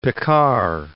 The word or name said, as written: Piccard